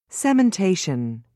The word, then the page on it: cementation: British English